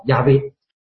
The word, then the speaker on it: 亚威: 这是今天被普遍接受的公认为是正确的发音方式是亚威。